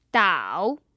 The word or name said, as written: dao3